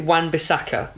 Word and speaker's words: Wan-Bissaka: I want to give a shout out to, I'm going to get his name wrong, is it Wan-Bissaka? – Yeah.